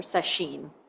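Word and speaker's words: sesshin: So setsu shin becomes session, or seshin is really how it would be pronounced.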